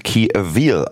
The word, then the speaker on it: Key Avil: Die Dame wird anscheinend Key Avil ausgesprochen, nicht Key Avil, wie ich es gerade von mir missraten aus meinem Mund kam.